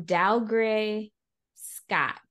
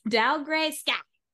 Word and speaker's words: Dougray Scott: Basically, okay, you say his name, Dougray Scott. Dougray Scott. – Interesting. – The way that they say Scott is it's spelled S-K-A-A-T, scat. Dougray Scott.